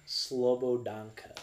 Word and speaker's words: Slobodanka: Okay, how do you say it? – Slobodanka. – Slobodanka. – Yes.